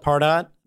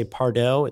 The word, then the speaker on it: Pardot: And as you said, we call it Pardot. You know, it's the easiest way to pronounce it when you read it. Probably about half the people out there will say Pardot. It sounds more sophisticated, like Target or Uber, which I haven't gotten to catch on yet.